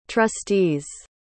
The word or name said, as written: trustees